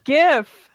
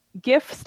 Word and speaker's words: GIF: Now, talking about things coming up, we're excited for another year of, we like to say gajif, because is it a hard G or a soft G? So we throw in both G's. We're not sure if it's GIF or GIF. – GIF, it's GIF.